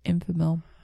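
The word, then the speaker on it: Enfamil: How do you say it? – Infomil.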